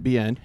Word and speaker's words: Bien: Again, my name is Brent Bien. Last name gets butchered all the time, but it's simply pronounced a B and an N.